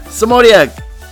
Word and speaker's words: Semotiuk: She hails from our neighbors in the north, Toronto, Canada, Natalie Semotiuk, Welcome, Natalie.